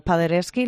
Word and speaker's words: Paderewski: Entre ellos, bueno, podemos destacar desde el segundo premio del concurso internacional de piano Paderewski, ¿lo he dicho bien? – Sí, o por ejemplo el concurso nacional de jóvenes pianistas, como decíamos, de la ciudad de Albacete.